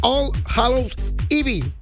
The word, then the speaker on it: All Hallows Eve: que es All Hallows Eve. ¿Algo así se pronuncia así? – Sí, es que por eso se hizo Halloween.